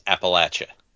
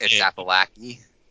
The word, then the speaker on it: Appalachia: I gotta do this. It's Appalachia. – Appalachia? – Yes. – Appalachy. – If you want an easy way to remember it, an easy mnemonic device is say it right, or I'm gonna throw an apple at you.